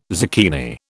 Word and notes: zucchini: British English

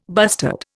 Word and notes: bustard: Indian

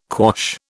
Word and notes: quash: British English

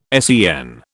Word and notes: SEN: British English

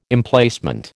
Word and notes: Emplacement: American English

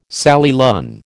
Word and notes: Sally Lunn: American English

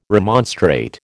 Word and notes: remonstrate: American English

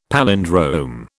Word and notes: palindrome: British English

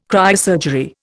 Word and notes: cryosurgery: Indian

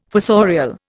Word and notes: fossorial: Indian

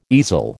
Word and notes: easel: American English

